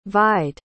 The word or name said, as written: vied